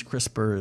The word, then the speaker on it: CRISPR: but it's CRISPR, like crisp. And I'm going to try to say it. Clustered regularly interspaced short palindromic repeats. Hence, CRISPR is a much shorter way to say that.